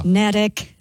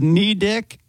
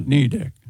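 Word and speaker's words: Neddick: It rhymes with medic, according to this. – Medic. – Medic, not Nedick. – It sits on the small Nubble Island. – What? – Nubble. N-U-B-B-L-E.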